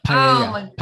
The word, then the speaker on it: Paella: And I went to Barcelona and I'm going to butcher the meal because now Pampaya? Is it Pampaya? The seafood meal? – I'm blanking on it too. Paella.